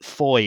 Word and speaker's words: Fowey: It's F-O-W-E-Y, and apologies to all friends in Cornwall for some of the indignities that are going to be committed on your beautiful language today. But Foy is how I pronounce it.